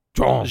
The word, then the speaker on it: Jean: Det er sådan, man udtaler det på fransk. – Er det sådan? – John.